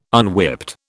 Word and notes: unwhipped: British English

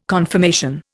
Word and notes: confirmation: Indian

